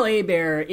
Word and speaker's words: Hebert: Was Kyle Herbert or Mark Britton? – I guess. Kyle Hebert is in the remaster version, which is the movie that we watched.